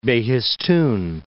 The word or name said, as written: Behistun